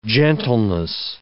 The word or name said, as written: gentleness